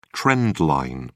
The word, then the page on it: trend line: British English